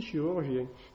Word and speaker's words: chirurgien: Ce qui explique, voilà, nous arrivons enfin à des mots, hein, par exemple, que dans, on dit chiropracteur, ça s'écrit CH, hein, d'accord, mais on dit bien chiropracteur, chiromancie, c'est la racine de la main, hein, et pourtant, on dit aussi chirurgien.